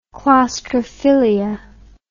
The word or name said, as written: claustrophilia